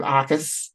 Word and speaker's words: Jacas: I know it's spelled Jacas, but it's not pronounced that way. It's Gabe Akas, and Seth Coleman is their other outside linebacker.